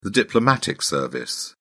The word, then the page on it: diplomatic service: British English